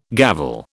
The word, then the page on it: gavel: British English